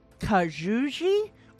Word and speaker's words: Kajouji: Well, this one doesn't scroll, so um, it all started to unfold with 18 year old Nadia, and I'm p- pretty sure her last name is p- is pronounced Kajouji. – Oh, that's a ha- Russian. – No, no it's K A J O U J I.